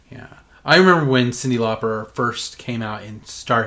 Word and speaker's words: Cyndi Lauper: But they said Cyndi Lauper, and then in parentheses it said rhymes with popper.